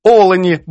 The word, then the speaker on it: олені: Надруковано, що олені йшли до водопою, це наголос російський. По-українському вимовляємо «олені», бо «олень».